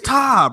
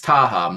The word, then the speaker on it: Taha: I thought nigga, I thought you pronounced the J. It's Taha, my bad.